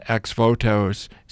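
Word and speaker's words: ex-votos: The new show is called Intimacy of Faith, Devotional Retablos and Ex Votos.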